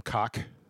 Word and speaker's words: Koch: He pronounces his last name Koch. K-O-C-H. Koch. Koch. Whatever.